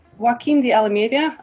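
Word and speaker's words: Joaquim de Almeida: The fact that you got Joaquin de Almeida, am I saying that completely butchering it? – Close enough.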